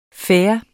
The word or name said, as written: fair